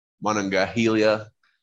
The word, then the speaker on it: Monongahela: The Monongalia. – Or how are you going to pronounce it? The Monongahela.